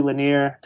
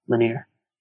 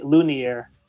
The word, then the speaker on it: Lanier: At my college graduation, my name was pronounced Ramsay Lunier. Like, just completely butchered it.